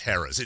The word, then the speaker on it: Harrah's: And he called it Haraz. And I went, oh, yeah, no, it's spelled like that. It's actually Harris.